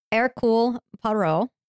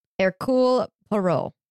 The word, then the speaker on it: Hercule Poirot: But yes, so anyway, Hercule Poirot.